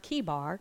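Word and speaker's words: Chebar: this is pronounced Kibar, that the heavens were opened, and I saw visions of God.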